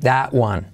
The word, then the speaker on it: that: I'm going to keep that one. All that one. La T de that casi no se dice. That one.